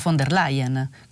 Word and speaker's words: von der Leyen: Infine, signora giornalista, la prego, si pronuncia von der Leyen con la F.